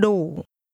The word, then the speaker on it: đủ: Nhưng đủ. Đủ. Đủ. – The word that means enough is pronounced with a falling, rising tone.